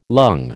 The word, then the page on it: lung: American English